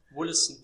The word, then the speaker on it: Wollaston: Dann habe ich gefunden, Assistenzlehrer in Wollaston, spricht man das so aus, Großbritannien. – Wollaston, ja.